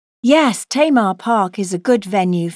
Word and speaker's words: Tamar: It's T-A-M-A-R.